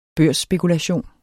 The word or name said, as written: børsspekulation